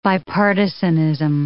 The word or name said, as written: bipartisanism